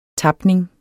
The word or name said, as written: tapning